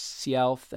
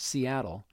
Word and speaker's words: Sialth: One day, an Indian named Sialth, pronounced Sialth and sometimes Seattle,